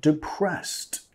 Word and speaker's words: depressed: O si es un poco más serio, I'm depressed. Estoy deprimido. – I'm depressed. – I'm depressed. – ¿Ok? I'm depressed. Nota la ED. En este caso estamos pronunciando como una T. Depressed. Depressed.